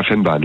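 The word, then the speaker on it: Vennbahn: Et alors, il y a une ancienne ligne, on parlait de la ligne du Vanbamme, c'est ça? – De la Fennbahn, oui, tout à fait. – Ouais, pardon, ça prononce comme ça, ok.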